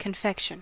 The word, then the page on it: confection: American English